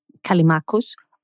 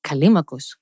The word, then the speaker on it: Callimachus: So basically, following now, you say Callimachus. I follow the modern Greek pronunciation, Callimachus.